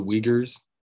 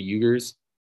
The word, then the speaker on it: Uyghurs: It's the Uyghurs, not the Uyghurs.